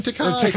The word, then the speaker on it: Takei: Because I did George Takei while you went to LeVar Burton. – Ah, you just mispronounced it. You could call them Takei.